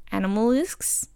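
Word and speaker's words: Animagus: how they got into the circumstance of being an animalgus. That's how you pronounce it.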